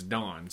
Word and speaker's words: Dan: Actually, it's spelt Dan, but say it's Don, so okay.